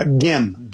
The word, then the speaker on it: Gim: You got David Gim. Is that how you pronounce his last name? – Gim. – Gim, that's right.